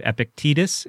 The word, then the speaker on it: Epictetus: And obviously you're familiar with Epictetus. Is that the correct pronunciation? – Indeed.